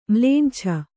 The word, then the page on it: ಮ್ಲೇಂಛ: kannada